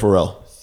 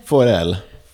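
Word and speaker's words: Forel: Pharrell. – Pharrell. – First sword to the Sea Lord of Braavos. – Ooh. Okay. So how do you say his last name again? – Pharrell.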